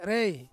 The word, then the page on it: rei: Ansus